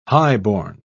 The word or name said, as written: highborn